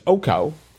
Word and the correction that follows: ocho: Ocho. – Ocho, which I know at least how to spell, if not pronounce.